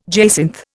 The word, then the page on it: jacinth: Indian